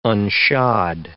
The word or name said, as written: unshod